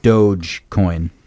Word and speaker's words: Dogecoin: The correct pronunciation of Dogecoin, cryptocurrency, is Dogecoin. Yep. Okay, so it's Dogecoin. You were right.